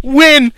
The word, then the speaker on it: Winn: I think I know the answer to this question, but a Supergirl aside, who is your favorite character whose name I think rhymes with Ben? – Win!